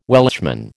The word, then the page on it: Welshman: American English